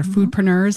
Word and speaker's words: foodpreneurs: It's really focused on breaking the barriers that often food entrepreneurs or foodpreneurs, am I saying that right? – Foodpreneurs. – Yep, foodpreneurs often have.